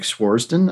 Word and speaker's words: Swardson: Am I saying his last name wrong? – Nick Swardson? I don't think so.